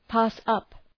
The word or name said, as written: pass up